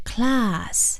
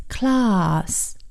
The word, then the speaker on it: class: 上课这个课，或者是也可以做班级来讲，是 class， C-L-A-S-S， class。 Class，那么这个啊，英式英语的读法呢，它是 a 读成 r，那么在这里的 class，这个 c l a s s 中间的 a 读成 r class。